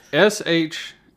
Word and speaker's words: Sharlto: So it's Sharlto. Right. Basically, the R is silent, essentially.